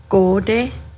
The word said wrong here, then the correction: gonde: And going back to eel, gonde.